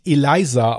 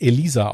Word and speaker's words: Elisa: Der Name wird nicht Eliza ausgesprochen, wie ich es des Öfteren gerne mal machen wollte. Der Name wurde wirklich Elisa ausgesprochen und der Familienname Lam.